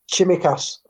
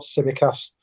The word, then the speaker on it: Tsimikas: We're also there, but everything about Tsimikas, I believe it's pronounced, not Tsimikas. I've seen a couple of Greek Liverpool supporters correcting Theo yesterday, so Tsimikas.